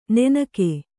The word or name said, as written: ನುಣ್ಣಗೆ